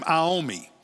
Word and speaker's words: Iommi: And the lead guitarist who helped start Black Sabbath is Tony, I think he pronounces his last name, Iommi, considered one of the best guitar players ever.